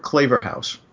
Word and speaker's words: Claverhouse: And I looked at the word Claverhouse. And the reason I'm pronouncing it that way is because clave is the past tense of the verb cleave.